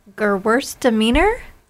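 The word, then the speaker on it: Gewürztraminer: It's Gewurztraminer. – Yes, you said it. You said it very nice. Yeah, you said it nice. It's in the same category. – Okay. – And Gewurztraminer, you probably said it better than I did, also has levels of dry to sweet.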